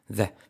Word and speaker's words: the: Fijaros cómo se pronuncia. The. Es básicamente como el sonido Z de zapato, de Zaragoza, z, pero activando las cuerdas, z, y la E no es exactamente D, sino z, un poco más entre E y A, z.